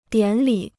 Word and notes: 典礼: diǎn lǐ

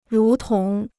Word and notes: 如同: rú tóng

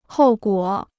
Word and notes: 后果: hòu guǒ

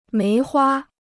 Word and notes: 梅花: méi huā

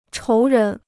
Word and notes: 仇人: chóu rén

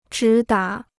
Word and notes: 直达: zhí dá

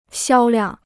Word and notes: 销量: xiāo liàng

